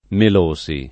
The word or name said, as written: Melosi